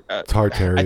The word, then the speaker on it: Tartaria: Wait, am I saying it wrong? Was I saying it wrong? – You said it right. No, I think you're Tartaria.